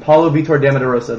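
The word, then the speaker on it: Paulo Vitor Damo da Rosa: And the only person who didn't make it, Paulo Vito Damodarosa, I can't say his name. Paulo Vitor de Amadorosa. There we go.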